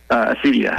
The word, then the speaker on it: acedia: And in particular, this concept of, and I don't even know how to pronounce it, but is it acedia, acadia? – Acedia. – Acedia.